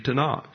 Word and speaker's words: Tanakh: And the acrostic is pronounced the Tanakh.